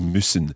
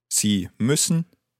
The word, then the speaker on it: müssen: Sie müssen. – But ich muss. – Ich muss. – So there's a difference in the vowel sound there. Sie müssen, but ich muss. – Ü. And U.